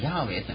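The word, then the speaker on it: ヤハウェ: で、そして、いろいろな研究から比較的最近になってですね、これは実はアドナイと仮に読むかって、ヤーウェイと読みました。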